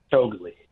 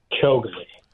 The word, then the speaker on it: Shiguli: Did I pronounce your last name the way you pronounce it? – Shiguli. You were very close. – No. Close at the Dennis Prager Show. Close is like in horseshoes. It doesn't matter. What? How do you pronounce it? – Shiguli. – I wasn't even close.